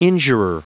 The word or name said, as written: injurer